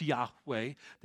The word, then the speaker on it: Yahweh: What God's name is Yahweh. Y-H-W-H. The ancient Hebrew language didn't have any vowels. It was consonantal language. They knew how to pronounce it. We do today. It's Yahweh. That is his name.